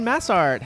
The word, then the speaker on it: Massart: It's Massart. It's like, it's two words, basically.